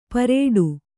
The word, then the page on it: ಪರೇಡು: Kannada